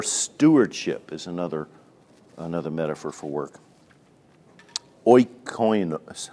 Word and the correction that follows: oikonomos: how do you say that? Oikonomos.